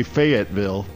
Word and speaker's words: Fayetteville: Or as some might say, Fayetteville. – Yeah, that too. That's another pronunciation. We don't like that one. – Maybe in West Virginia they do pronounce it Fayetteville.